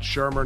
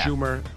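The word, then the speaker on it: Shurmur: If you are a huge Giant fan, you have to know it's Pat Shermer, not Schumer.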